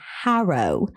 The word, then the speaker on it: Harrow: And it's Harrow, London, just saying. – Oh, Harrow. – I'm not trying to be cheeky or anything, but that is how it's pronounced.